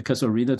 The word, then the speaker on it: Casuarina: Yes, and also it is part of the story collection, The Casuarina Tree. Am I pronouncing that correctly? – Yes, The Casuarina Tree.